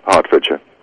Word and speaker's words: Hertfordshire: It's spelled like it should say Hertfordshire. – But it sounds like Hartford, Connecticut. It's pronounced like that. Yeah, Hertfordshire.